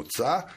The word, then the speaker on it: ca: E, mi ne kažemo ča kao većina e, istarskih područja, nego kažemo ca mhm.